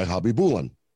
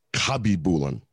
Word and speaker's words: Khabibulin: Right now, that's how you and I said his name, right? Right. Believe it or not, in one of the early, early pronunciation guides, they listed him as Habibulin.